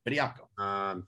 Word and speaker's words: Bediako: Charles Bediako, is that how you pronounce it? – Hey, very good. Yep, Bediako.